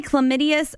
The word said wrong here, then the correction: calamitous: Did you say chlamydia? – Chlamydious? Calamitous. See if you know what this word means. – Calamitous? Calamitous? – Spell it for me. – Calamitous. Like calamity? Calamity? – Yeah.